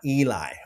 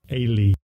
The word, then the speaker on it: Eli: It's not Eli, but let me play it for you. – Strong's H, 5941, Ailey, Ailey. – Okay, so according to the Strong's Strong's gentleman there that pronounces these names, it's Ailey, Ailey.